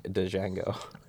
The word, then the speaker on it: Django: It's a silent D. Like, Django.